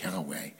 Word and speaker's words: Yahweh: so they would just write the four letters, which we now think of as being pronounced Yahweh.